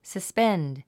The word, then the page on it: suspend: səspénd; サスペンド